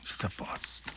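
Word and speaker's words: Sisyphus: 우리식으로 읽으면 시지프스. 예. 이 친구들 뭐 발음 좀 이상하게 하죠. 시지프스. 예. 그냥 시지프스라고 하면 돼요, 우리는.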